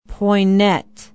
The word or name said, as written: Poynette